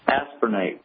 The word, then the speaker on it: aspirinate: Copper aspirinate is spelled copper, C-O-P-P-E-R. Next word, okay. – A-S-P-I-R-I-N-A-T-E, like aspirin with the word eight, A-T-E on the end.